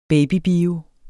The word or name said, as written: babybio